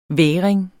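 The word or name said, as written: vægring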